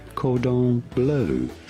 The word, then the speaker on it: cordon bleu: Is that how you pronounce it? Do you have standing by? – I do have. – Well, let's hear him. – It is said cordon bleu.